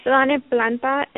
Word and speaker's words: Tlalnepantla: It's Tlalnepantla, and the spelling is T-L-A-L-N-E-P-A-N-T-L-A.